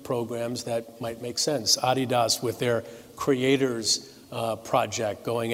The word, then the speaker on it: Adidas: I appreciate that you pronounce Adidas the right way, Adidas. That's right, founded by Adi Dassler.